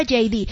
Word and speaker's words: Heidi: Se escribe Heidi, se pronuncia JD.